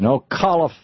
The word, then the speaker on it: Ocala: James in Ocala, Florida.